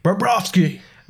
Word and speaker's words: Bobrovsky: And then your goalies, you've got Sergei Bobrovsky. Did I even say that right? I don't know. – Bobrovsky.